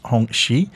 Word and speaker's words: Hongqi: You might know it as being spelt as Hongqi, but it's pronounced Hongqi.